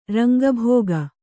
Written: ರಂಗಭೋಗ

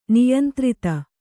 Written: ನಿಯಂತ್ರಿತ

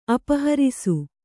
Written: ಅಪಹರಿಸು

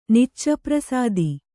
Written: ನಿಚ್ಚಪ್ರಸಾದಿ

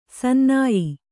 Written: ಸನ್ನಾಯ್ಯ

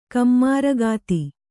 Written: ಕಮ್ಮಾರಗಿತ್ತಿ

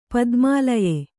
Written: ಪದುಮಾಲಯೆ

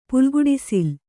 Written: ಪುಲ್ಗುಡಿಸಿಲು

